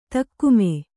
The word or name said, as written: ದಗಮು